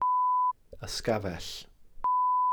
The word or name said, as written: ysgafell